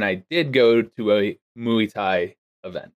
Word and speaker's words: Muay Thai: Is that how it's pronounced? Muay Thai? – Muay Thai. – Not Muay Thai? Muay Thai? – I literally made sure I've triple check. It's Muay Thai.